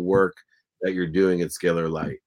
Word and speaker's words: Scalar Light: There he is, the researcher at Scalar Light, and I hope I pronounced that correctly.